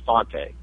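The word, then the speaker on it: Fante: Well, you know, different people pronounce the name different ways, but the way that the family pronounces it is Fonte. – Okay. – Even though he's of Italian origin. Fonte sounds like a French pronunciation, but yeah, he's a wonderful writer.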